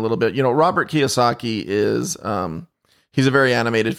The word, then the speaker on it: Kiyosaki: Robert, and I hope I don't know, Robert Kiyosaki, is that the correct pronunciation? – Yeah, Robert Kiyosaki.